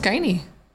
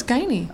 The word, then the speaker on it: Guinea: It's pronounced Guinea. – What? – You've never had to say my last name. – I have. – It's Guinea. – Oh, okay. – It's very Irish.